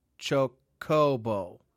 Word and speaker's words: Chocobo: Chocobo. Like hobo.